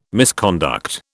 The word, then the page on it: misconduct: British English